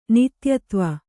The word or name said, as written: ನಿತ್ಯತ್ವ